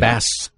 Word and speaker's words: Bas: Well, why are you pronouncing it Boz, though? – Right. – Probably like Baz or something. – Exactly. – Bass. – B-A-S, right? – Bass Rutten. – Boz would connote a Z, right? – Right.